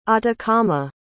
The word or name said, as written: atacama